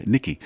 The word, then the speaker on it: Nyqki: And interesting enough, she spells her name N-Y-Q-K-I, Nikki.